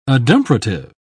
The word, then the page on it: adumbrative: British English